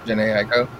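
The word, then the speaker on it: Jhené Aiko: Is it Janae Aiko? – Janae Aiko.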